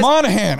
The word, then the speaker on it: Monaghan: Because Monahan. I don't know why you don't call him Monahan. – Fucking. – The G is silent. – Not in my world. It's a Gan. Monahan, you fucker.